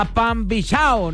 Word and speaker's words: Pambichao: Nos vamos a quedar con música a Pimba Chao, siempre lo he dicho mal, me acabo de dar cuenta. A Pambichao, nos dice Manuel Turizo.